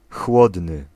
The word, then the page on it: chłodny: [ˈxwɔd̪n̪ɨ]